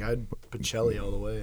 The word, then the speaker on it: Pichelli: Yeah, I've been saying it wrong. It's Pichelli.